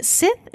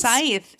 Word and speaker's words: scythe: It's got its, is it a Sith? Is that how it's pronounced? – Scythe. And Scythe.